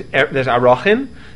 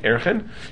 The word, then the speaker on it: Arachin: But Chazal understood, and tradition, that those that there's two categories, there's Arachin, in popular pronunciation, Erechin, and Nidarim, and Damim.